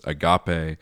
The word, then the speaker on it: agape: We are now to the fourth of these loves, which, as Lewis says, is agape, which we would pronounce agape.